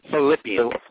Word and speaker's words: Philippians: Philippines? What? How do you say that? How do you say that chapter? – Philippians.